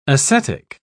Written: ascetic

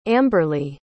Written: amberly

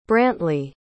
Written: brantley

